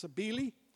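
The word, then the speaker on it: Cybele: Smyrna was designed and it was set out to be a giant symbolic idol of its patron goddess, Sabili, or Sabali, I think it's pronounced Sabili.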